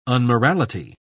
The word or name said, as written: unmorality